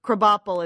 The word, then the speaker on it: Krabappel: And for those of you that don't watch The Simpsons, Krabappel is actually crab apple. Just... spelled, just pronounced Krabappel.